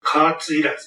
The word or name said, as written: kaatu training